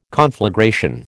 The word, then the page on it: conflagration: American English